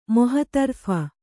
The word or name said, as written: ಮೊಹತರ್ಫ